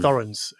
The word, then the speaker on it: Thorens: Sorry, Thorens is how you say it in English.